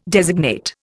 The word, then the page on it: designate: Indian